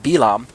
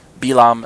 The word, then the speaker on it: Bilaam: Balak is asking Bilaam to curse Israel. Balaam is how most of you are used to hearing his name pronounced in English circles, Balaam.